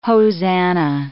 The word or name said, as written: hosanna